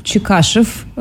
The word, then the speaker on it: Чекашев: Чекашев. – Чекашев. – Да, правильное ударение на фамилию.